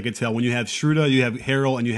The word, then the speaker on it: Schröder: I'll pronounce it in the German vernacular. – Schröder.